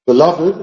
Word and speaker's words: beloved: Beloved, beloved, beloved, la sílaba remarcada es love. Entonces, es la L ahí que cuenta.